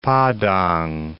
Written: Padang

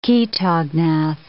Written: chaetognath

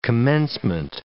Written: commencement

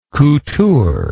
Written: couture

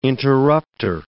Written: interruptor

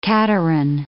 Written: cateran

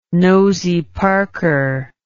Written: nosy parker

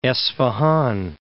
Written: Esfahan

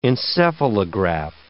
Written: encephalograph

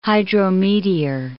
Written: hydrometeor

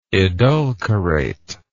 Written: edulcorate

